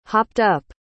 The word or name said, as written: hopped-up